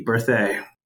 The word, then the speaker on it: Berthay: As my wife likes to say, it's like birthday without the D, Berthay.